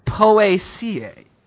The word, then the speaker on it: Poaceae: Scientific Latin name, pronounced, according to Wikipedia, Poaceae.